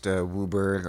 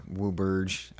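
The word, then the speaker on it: WUBRG: Usually pronounced Wooburg or Wooburge.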